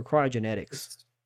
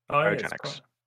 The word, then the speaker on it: cryogenics: Is it cryogenics or cryogenetics? – Cryogenics. – Okay. – Like cryogenic storage.